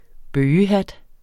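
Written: bøgehat

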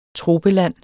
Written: TROPELAND

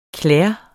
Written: klæde

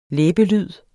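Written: læbelyd

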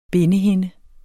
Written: bindehinde

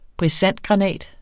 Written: brisantgranat

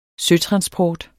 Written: søtransport

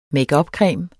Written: makeupcreme

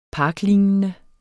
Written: parklignende

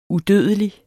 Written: udødelig

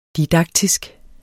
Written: didaktisk

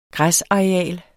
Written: græsareal

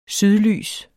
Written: sydlys

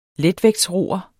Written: letvægtsroer

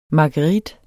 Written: marguerit

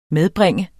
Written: medbringe

